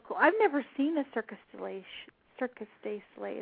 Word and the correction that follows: Cirque du Soleil: Is that how you say it? – Circus. Uh, it's Cirque du Soleil. Cirque du Soleil. Mm-hmm. Totally mm-hmm. had that wrong. Okay. It's French.